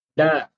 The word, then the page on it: d: Sanskrit